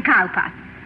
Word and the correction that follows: Cowper: Cooper, darling. – Hmm? – It is spelled Cowper, but pronounced Cooper.